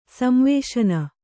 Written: ಸಂವೇಶನ

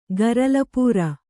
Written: ಗರಲಪೂರ

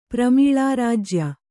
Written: ಪ್ರಮೀಳಾರಾಜ್ಯ

